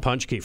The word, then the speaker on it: Paczki: Paczki, friends.